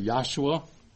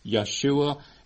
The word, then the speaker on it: Yeshua: I know a lot of the sacred names groups will say Yahshua, and you have a lot of messianics, some very learned Jewish scholars say, no, that's not the way they pronounced it then. It was Yeshua, and perhaps even the shorter form, Yeshu.